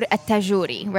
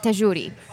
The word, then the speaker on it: Tagouri: So, my last name is spelled Tagouri, right? In Arabic, my name is Noor Tagouri, right? So, you said Tagouri.